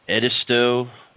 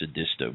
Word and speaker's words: Edisto: Edisto, it's spelled Edisto. Like, you know, if you're from California, you'd see it and it'd say, oh, it's Edisto Beach. – Modesto without an M.